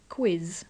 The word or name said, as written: quiz